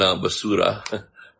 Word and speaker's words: basura: And basura, pronounce it correctly.